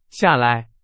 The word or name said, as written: 下来